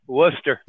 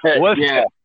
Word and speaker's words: Worcester: And dispatch told me to take it down to the rail yard and let's pronounce it correctly so the people in the Boston area don't get pissed off. Worcester. – What is it? – Worcester.